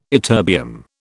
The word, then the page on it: ytterbium: British English